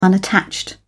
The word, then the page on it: unattached: British English